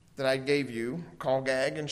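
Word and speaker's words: chagag: How are you planning to call gag the feast? I'll spell that for you. You might like it for your notes. It's a Hebrew word, and it's C-H-A-G-A-G. C-H-A-G-A-G, and it's pronounced call gag.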